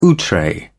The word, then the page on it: outre: British English